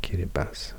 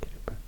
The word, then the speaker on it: Kiribati: So Kiribati, this is actually pronounced Kiribati, I've learned Kiribati.